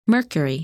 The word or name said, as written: Mercury